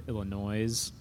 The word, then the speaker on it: Illinois: A lot of people outside of, even like I've found some I don't know if I've heard an Illinois person say that, but you'll hear it kind of all across the nation. Somebody say Illinois, pronounce the S.